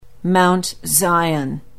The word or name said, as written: Mount Zion